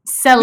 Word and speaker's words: Celine: Celine. – Yeah, it was like, there's an E at the end, but you don't pronounce the E.